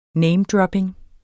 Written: namedropping

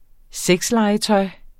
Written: sexlegetøj

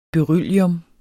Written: beryllium

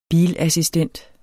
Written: bilassistent

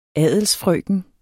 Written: adelsfrøken

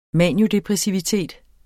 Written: maniodepressivitet

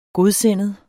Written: godsindet

